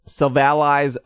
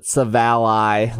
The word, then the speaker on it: Silvally: Silvally's drives. Also, I think we've learned that it's pronounced Silvally, right? That's how the anime says it.